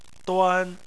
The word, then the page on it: duan: 1